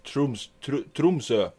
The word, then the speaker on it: Tromsø: Tromsø. Am I pronouncing that properly? – Oh, it's Tromsø.